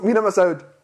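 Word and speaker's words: Mina Massoud: ¿Cómo se pronuncia? ¿Mena o Mina? – Mina. – O... – No, pues tú lo vas a pronunciar mal. A ver, pronúncialo. Pronúncialo para mí, por favor. – A ver, ahí les va, agárrense, hijos de su Mina Masour. – Más o menos, oye, más o menos. Mina Masoud. Mina Masoud.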